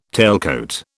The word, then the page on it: tailcoat: British English